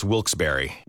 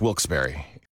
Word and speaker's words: Wilkes-Barre: Now, I had heard that it was supposed to be pronounced Wilkes-Barre. Wilkes-Barre Scranton's 97.9 X. The only way to pronounce Wilkes-Barre.